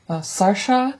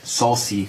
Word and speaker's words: Saoirse: S-A-O-I-R-S-E. – Ronan. Und anscheinend spricht sie ihren S irisch. Sie spricht ihren Namen Sascha aus. Sascha, aber so wird er anscheinend in Irland eigentlich nicht ausgesprochen. – Aber wir wissen auch nicht, wie er ausgesprochen ist. – Auf IMDb steht eine Lautschrift, wie er wirklich ausgesprochen ist. Mein Vater meinte, es müsste Saucy gesprochen werden, aber es ist wahrscheinlich auch falsch.